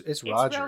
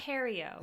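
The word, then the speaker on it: Rogério: It's Roger. – It's Rogerio. But... I just forgot how to pronounce words. – There you go. – It's, oh my god. – With the, yeah, Rogerio. – The accents over the E.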